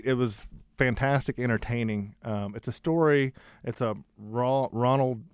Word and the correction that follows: Roald Dahl: I don't know how to pronounce his name. – Roald Dahl.